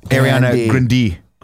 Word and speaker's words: Ariana Grande: You're saying it wrong, Tim. I'm sorry. It's Ariana Grande.